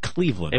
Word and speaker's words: Clevlen: It's spelled the same way, Cleveland, C-L-E-V-L-E-N, but they pronounce it Cleveland.